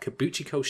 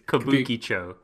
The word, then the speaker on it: Kabukicho: Kabukicho.